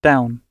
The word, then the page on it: down: British English